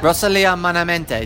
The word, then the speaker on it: Rosalía: Rosalía Malamente.